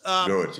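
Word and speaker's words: Gewirtz: I want to ask you about Gewurz, or how do you pronounce his name? Gerwitz? – Gerwitz, yeah.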